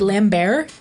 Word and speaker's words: Lambert: Are you saying it like Lambert? – Yeah. – Without pronouncing the T? – Yeah, he's French.